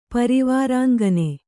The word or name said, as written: ಪರಿವಾರಾಂಗನೆ